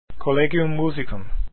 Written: Musicum

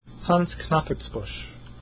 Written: Knappertsbusch

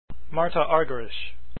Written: Argerich

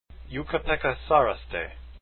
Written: saraste

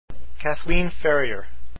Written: Ferrier

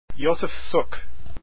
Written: suk

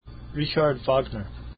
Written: Wagner

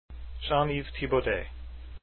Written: Thibaudet

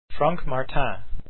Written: Martin

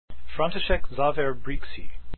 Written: Brixi

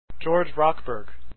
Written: rochberg

